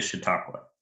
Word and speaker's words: Chautauqua: Uh, is it Chautauqua? Yes, it is Chautauqua.